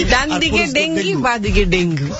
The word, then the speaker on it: ডেঙ্গু: হ্যাঁ ডেঙ্গুই না অরিজিনাল উচ্চারণ হবে ডেঙ্গি